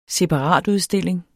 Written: separatudstilling

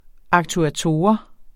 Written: aktuator

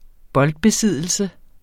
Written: boldbesiddelse

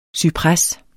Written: CYPRES

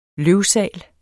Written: løvsal

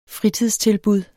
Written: fritidstilbud

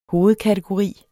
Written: hovedkategori

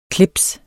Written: CLIPSENES

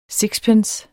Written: SIXPENCER

